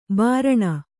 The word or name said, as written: ಭರಣ